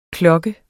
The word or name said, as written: KLOKKES